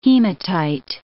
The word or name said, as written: hematite